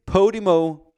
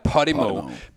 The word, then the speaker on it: Podimo: Vi lagde ud med at snakke om the unicorn to be Podimo, som det skal udtales. Ikke Podimo, det er Podimo.